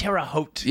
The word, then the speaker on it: Terre Haute: I don't even think I'm pronouncing that correctly. It's probably Haute. – It's Terre Haute.